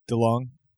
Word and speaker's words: DeLong: DeLong.